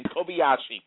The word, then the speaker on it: Kobayashi: That's how you say his name. Kobayashi.